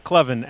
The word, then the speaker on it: Clevon: Am I saying that right? Clevon? Clevon, absolutely. Okay. Just Ta- like clever.